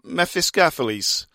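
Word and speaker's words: Mephistopheles: I used to call them the Mephiscophels, but they're actually Mephiscopheles, which makes more sense now. Mephiscopheles. Does that, I don't know. It looks like I don't care. Mephiscopheles from New York City are headlining.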